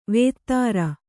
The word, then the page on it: ವೇತ್ರ: Kannada